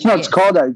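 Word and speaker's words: Škoda: Skoda. – It's Skoda. Right. It actually has got S with a little thing on top, all right? So it's a little accent. It's pronounced sh as SH, all right?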